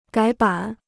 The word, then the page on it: 改版: gǎi bǎn